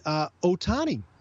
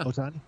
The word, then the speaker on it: Ohtani: And they've signed the Ohtani. Is that how you say it? – Sure, yeah. – Ohtani. – I mean, my Japanese is rough, but yeah, I'd say so. – Shohei Ohtani.